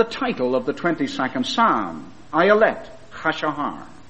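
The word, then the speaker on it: Ayelet Hashahar (אילת השחר): "Well," he said, "we pronounce it like this, and I think I have it correctly." He said, "we call it Ayelet Hashahar. Ayelet Hashahar."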